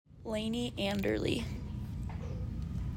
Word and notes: Layni Andrle: LAY knee an DER lee

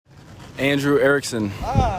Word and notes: Andrew Erickson: an DREW ERR ick sin

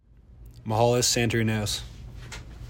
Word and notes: Mihalis Santorineos: ME ha LISS SAN ta REE noss

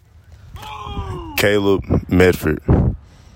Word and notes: Caleb Medford: KAY lub MED ferd

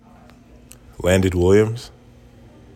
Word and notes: Landon Williams: lan DIHN WILL yums

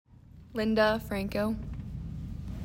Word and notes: Linda Franco: LIHN duh FRANK coe

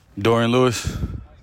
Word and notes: Dorian Lewis: DOOR ee in LEW iss

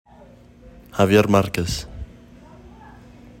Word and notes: Javier Marquez: ha VEE air mark ezz

